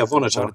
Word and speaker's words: Vonotar: Now, how did you come up with Vonotar? He's like my. Is that am I saying that correctly? I don't. – Yeah, Vonotar. Vonotar. Yeah.